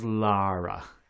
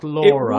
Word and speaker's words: Lara: Don't let the Americans get you with their pronunciation. Her name is Lara. Not Laura.